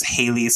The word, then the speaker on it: Heighleigh: My name is Haley, spelled with way too many vowels for no reason. I am in it. H-E-I-G-H-L-E-I-G-H.